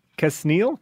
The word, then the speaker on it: Quesnell: So uh, this fella, whose name was Constant Kesnell, Kesnell. It's spelled Q U E S N E L L.